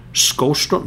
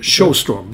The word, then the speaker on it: Sjöström: Och så varför, how do you pronounce that name? Skåström? No, Sjö. – Ja, det gör ju inte. – Nej, det går inte. Sjöström blev det.